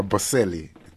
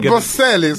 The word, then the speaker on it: Bocelli: Yeah, featuring Andrea Bocelli, Italian Bocelli. – That was Ed Sheeran. And that featured Andrea Bocelli. – I said Bocelli, so Bocelli.